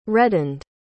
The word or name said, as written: reddened